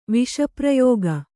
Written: ವಿಷಪ್ರಯೋಗ

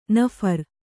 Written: ನಫರ

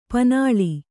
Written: ಪಣ್ಣೆಲೆ

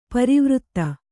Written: ಪರಿವರ್ತ

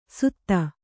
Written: ಸುಧ

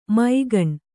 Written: ಮೈಗಣ್

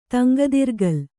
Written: ತಂಗದಿರ್ಗಲ್

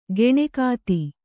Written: ಕಣ್ಗೆತ್ತು

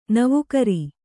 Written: ನವುಕರಿ

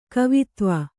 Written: ಕವಿತ್ವ

